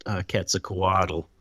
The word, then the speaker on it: Quetzalcoatl: So we were discussing that you just correctly pronounced Quetzalcoatl.